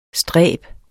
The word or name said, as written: stræb